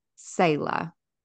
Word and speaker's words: Selah: And you've written Selah. Is that, am I pronouncing that right? – Yes.